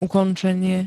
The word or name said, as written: ukončenie